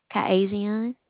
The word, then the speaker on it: Kyasion: Kaizian. – How do you spell that? – K-Y. – Okay. – K-Y-A-S-I-O-N.